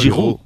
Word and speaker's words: Giroud: ha rendido más cuando ha tenido a Giroud como total, ¿cómo lo has dicho esto? Mucho gol. Giroud. Qué bien te ha quedado, este francés.